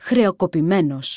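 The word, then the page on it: χρεωκοπημένος: Greek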